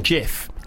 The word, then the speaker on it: GIF: Did you know that it's actually pronounced Jif? It's meant to be pronounced Jif.